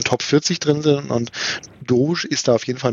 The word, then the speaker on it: Doge: Äh, ja, das heißt richtig, Doge. Doge.